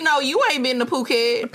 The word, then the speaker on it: Phuket: It is Phuket. I thought it was fucky.